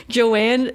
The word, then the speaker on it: Joan: It's Joanne, and the first one's J-O-A-N-N-E, and Joanne, and the last one is J-O-A-N. Am I saying those wrong? – That's Joan.